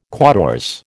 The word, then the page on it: quatorze: American English